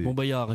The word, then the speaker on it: Montbéliard: Montbéliard, effectivement, on prononce pas le ouais. – L.